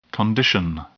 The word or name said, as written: condition